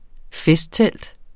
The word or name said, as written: festtelt